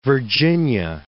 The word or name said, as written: Virginia